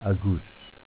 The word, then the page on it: Aguss: dialetto